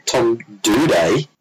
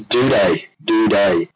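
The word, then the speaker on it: Doedee: Tom Duday, as it's pronounced, played his first game. – Isn't it Doady? It's Duday.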